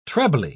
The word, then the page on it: trebly: British English